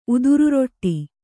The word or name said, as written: ಉದುರುರೊಟ್ಟಿ